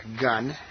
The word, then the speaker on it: Gunn: And again, if you have any questions, my name is John Gunn, G-U-N-N.